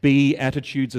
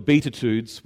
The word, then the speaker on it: Beatitudes: These aren't, for those of us who haven't seen or heard this word pronounced before, it's pronounced Beatitudes, as in the attitude you should be, not the Beatitudes, which to me sounds like a 1960s guitar pop band.